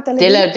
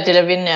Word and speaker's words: Delevingne: Und Cara Delevingne, wie spricht man die aus? – Cara Delevingne. Delevingne, er hat jetzt auch so gesagt, ja.